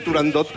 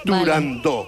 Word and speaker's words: Turandot: pues yo también lo digo mal y digo turandot como todo el mundo. Pero para que quede claro... – todos tontos ya. – Para que quede claro, es turandó.